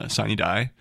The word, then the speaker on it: sine die: sine die. Am I pronouncing that right? – Yes.